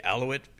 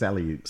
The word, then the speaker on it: Aleut: The Aleut, I don't know if I'm saying this right, forgive me, I think it's Aleut. – Some of these native names. – Aleutian. – Mm-hmm. Aleutian, yeah, Islands. Aleut. Okay.